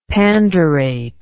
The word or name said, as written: pandurate